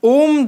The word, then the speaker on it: ओम: ओम जो उच्चारण है वो मेनली तीन स्वरों से मिलकर बना है अ उ और म तो जब हमने ओम उच्चारण करना होता है तो हमें अ ऊ और म ये तीन को एकदम same time gap same time provide करते हुए बोलना होता है